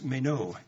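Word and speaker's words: meno (μένω): The word is meno, M-E-N, long O.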